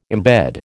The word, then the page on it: embed: American English